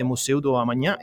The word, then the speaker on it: Museu do Amanhã: And like I said, today we have the party at the Museu do Amanhã. Is that correct? – Yes, that was a perfect pronunciation.